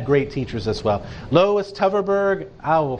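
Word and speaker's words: Tverberg: But I do know she pronounces it Teverberg, even though it's TV, it begins TV, but it's pronounced Teverberg.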